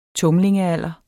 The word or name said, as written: tumlingealder